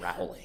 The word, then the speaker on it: Rowling: Rowling was, or I know it's pronounced Rowling.